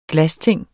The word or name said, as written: glasting